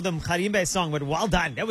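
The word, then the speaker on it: Mgarimbe: Just say mkharimbe. Just like that. Mkharimbe. Yes, there we go.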